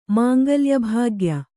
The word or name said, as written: ಮಾಂಗಲ್ಯಭಾಗ್ಯ